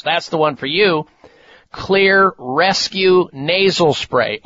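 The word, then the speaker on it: Xlear: Look for, ask for, Clear, spelled with an X, X-L-E-A-R, Nasal Sprays.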